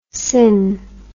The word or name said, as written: syn